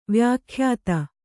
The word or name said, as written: ವ್ಯಾಖ್ಯಾತ